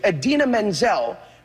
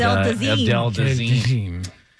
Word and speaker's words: Idina Menzel: The iconic and brilliant Idina Menzel, pronounced exactly as it is spelled, is our Elsa. – Just a throwback to when John Travolta called her what? – Adele Dazeem.